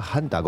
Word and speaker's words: hantavirus: Que sepas que Daniel López Acuña dijo hantavirus. – López Acuña decía hanta, con la H aspirada. – Y no sabemos quién le puso el nombre. – No sé, nosotros lo decimos con la H muda, sí.